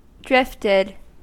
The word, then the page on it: drifted: American English